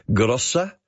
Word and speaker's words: Grosser: Another one, Keith, thank you, says, Bob Jane had that Benz, a 600 GROSSER, pronounced presumably Grossa, a 6.3-litre V8, more commonly used by dictators, Eastern dictators.